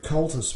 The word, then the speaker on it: Comhaltas: Cultus, by the way, is spelt C-O-M-H-A-L-T-A-S.